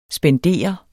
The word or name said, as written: spendere